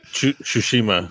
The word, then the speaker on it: Tsushima: If you're going to write it wrong in the show notes, you should probably go find the right name so that you can correct yourself. – Yeah, that's what I did. – What's the wrong name? – I did it to myself. Sashimi is incorrect. Shishima. Shishima is the correct answer, is the correct pronunciation. But for the life of me, I can't stop screwing those up because I like sushi a lot and it annoys me.